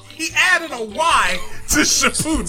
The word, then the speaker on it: Shippuden: Shippuden. – Shippuden.